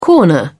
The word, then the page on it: corner: British English